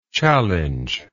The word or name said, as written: challengeable